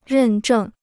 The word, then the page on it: 认证: rèn zhèng